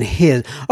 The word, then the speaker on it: hilsen: I pronounce as in his. Okay, so May Venlig His, may venle his, which means with friendliest regards.